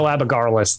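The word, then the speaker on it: Abagarlas: Abagarless. It's Abagarless. That's how I think that's how you pronounce it.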